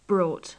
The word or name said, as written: brought